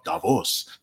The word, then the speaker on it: Davos: Oh, Davos, for all those people who want us to pronounce it properly.